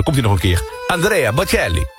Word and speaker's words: Andrea Bocelli: Andrea Bocelli in het Italiaans. Ik moet het ook altijd zo uitspreken. Andrea Bocelli in het Italiaans.